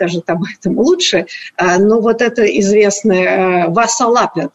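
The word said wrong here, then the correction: Vasaloppet: да, я правильно произношу? – Васалопет, лопет. – Лопет, да, да. Васалопет.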